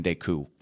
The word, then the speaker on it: Decoud: Tristan Decoud. The second D is silent.